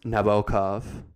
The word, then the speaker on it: Nabokov: We talk about Nabokov, who Allison tells us, if you want to sound smart at cocktail parties, it's actually pronounced Nabokov, Nabokov. We talk about Lolita itself,